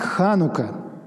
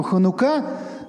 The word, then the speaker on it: Ханука: Праздник Ханука. Ну, конечно, в еврейском ударении идет на последнюю букву Ханука, но принято у нас в русской версии праздника называть Ханука.